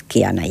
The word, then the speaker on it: Kihana: Bueno, el caso es que la H muda y es Kihana ya.